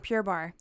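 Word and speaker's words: Pure Barre: It's spelled Barre, like Barre.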